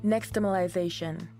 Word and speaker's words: Nixtamalization: Nixtamalization.